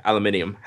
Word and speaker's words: aluminium: You're saying it wrong, by the way. – Yeah, aluminium.